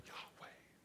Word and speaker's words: Yahweh: How do you say that? Well, it sounds like Yahweh.